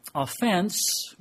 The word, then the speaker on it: offense: It has two different pronunciations depending on use, and according to the dictionary, offense, when you emphasize the second syllable, has the idea of a violation or breaking of social or moral rule, transgression, or sin.